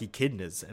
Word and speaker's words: echidna: Like, wait, is that how that word is pronounced? – Echidna, yeah.